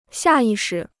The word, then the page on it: 下意识: xià yì shí